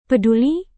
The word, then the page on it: peduli: Indonesian